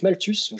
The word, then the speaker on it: Malthus: Non, en fait, c'était Malthus, je vais prononcer à la française.